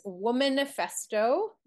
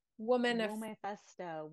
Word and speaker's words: Womanifesto: So I'm going to have you read, I love the title of this piece, Womanifesto. It's manifesto, but WO in the front. Like, whoa, how do you say it? – Womanifesto.